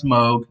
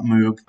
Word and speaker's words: Moog: It's pronounced Moog, not Moog.